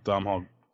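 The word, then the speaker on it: Domhnall: It started playing Donald Gleason.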